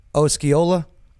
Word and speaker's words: Osceola: Osceola.